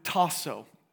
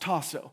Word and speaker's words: tasso: The Greek root of all three of those words, one of the words is just the root word, is the Greek word tasso. That's how it's pronounced, tasso.